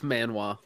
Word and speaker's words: manhwa: It was a Korean manhwa, not manga, manhwa, I think. – It's manhwa. – Manhwa? Yeah. I did say it right.